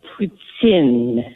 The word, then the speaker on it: poutine: But I always thought that's how you say poutine, like in Quebec or in Montreal or whatever. – No. – It's actually pronounced poutine. – Not poutine.